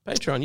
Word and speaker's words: Patreon: Did I say that wrong again? – Patreon, you said it.